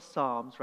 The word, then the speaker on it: Psalms: The Psalms come from the etymology, the root of the word Psalms, psalms, right? So the P is silent, but it's pronounced Psalms.